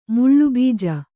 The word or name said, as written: ಮುಳ್ಳುಬೀಜ